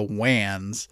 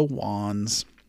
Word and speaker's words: Wands: Now, because I work in tech, I pronounced it like a wide area network or the WANs, but nope, it is the Wands.